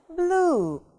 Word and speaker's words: blue: Тоже обратили внимание на транскрипцию, мы тянем буковку U, да? Blue. Голубой. Хорошо.